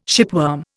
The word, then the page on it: shipworm: Indian